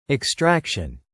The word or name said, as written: extraction